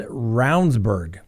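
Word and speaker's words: Ravnsborg: Jason Roundsburg, that's the way he pronounces it.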